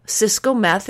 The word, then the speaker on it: Cisco Meth: The beautiful thing is that if you Google Cisco Meth, it's spelled just like computer accessories and the drug, you'll find me.